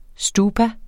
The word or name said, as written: stupa